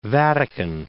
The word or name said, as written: Vatican City